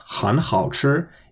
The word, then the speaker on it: 很好吃: Firstly, is that of course, putting that third tone before 好吃 turns it into a double third tone exception rule. So I pronounced 很好吃, even though 很 is technically a third tone.